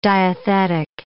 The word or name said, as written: diathetic